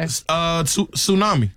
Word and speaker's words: tsunami: This has a silent letter in it. – Okay. – Tsunami. – Tsunami. – Tsunami. – Yeah. Sure does. – Now, some people might say tsunami. – Tsunami. – Tsunami. – Yeah. – Somebody might say that. No. They'd be wrong.